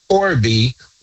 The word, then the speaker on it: Corby: Corby Bush Ministries. Now, my name is spelled C-O-R-B as in boy, Y. Corby Bush, B-U-S-H, Corby Bush Ministries on Facebook.